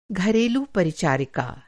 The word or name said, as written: परिचारिका